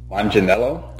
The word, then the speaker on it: Manganiello: Manganiello? – Okay.